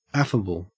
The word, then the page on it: affable: Australian English